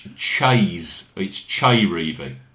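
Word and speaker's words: Chez Reavie: Ches Reeve? – Well, this debate goes on not just in this country but in America. I've always been led to believe that it's Chay Reeve rather than Ches.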